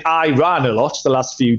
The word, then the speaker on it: Iran: So I was a political major, political science major in college, and everyone pronounced it Iran in college, like all the students. But our political science professors, he always corrected us and said it is Iran.